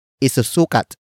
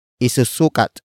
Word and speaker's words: isusukat: Word for word, it's isusukat niya ang sombrero. Isusukat niya ang sombrero. Isusukat niya ang sombrero. The first word is isusukat. Isusukat. Isusukat. Isusukat is spelled as I-S-U-S-U-K-A-T.